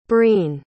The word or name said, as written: breen